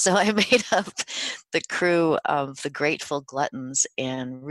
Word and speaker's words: krewe: Now, we should designate for listeners, crew here in the New Orleans context is spelled K-R-E-W-E, and that refers to those magnificent floats we see in Mardi Gras parades.